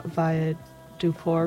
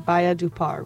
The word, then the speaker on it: Valledupar: Vallenato means born in the valley because this genre originated in the Valledupar region. Sorry, I pronounced that wrong. Valledupar region. But